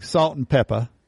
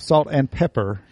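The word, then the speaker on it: Salt-N-Pepa: No, it's not Salt and Pepper. – Peppa. – Salt in Peppa, mm-hmm. – I think is how they would pronounce it.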